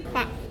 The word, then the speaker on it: Pak: What should I have said? – Pak. – Okay, so Pak itu benar, salah apa? – Betul, Pak.